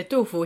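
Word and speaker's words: Du Fu (杜甫): And so this article not only talks about Li Bai, but also his friendship with Du Fu. Is that how you say his name? – Yeah, Du Fu.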